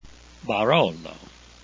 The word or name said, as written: barolo